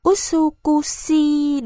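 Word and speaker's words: Usukushi (美しい): Nhấn mạnh âm U, và bỏ âm cuối y. – Các bạn khác nhắc lại. – Usushi là đẹp. Lúc ấy, Miyazaki nhận ra rằng mình phát âm tiếng Nhật sai. – Đọc là Usukushi đúng không?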